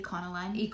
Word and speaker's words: Econoline: Econoline.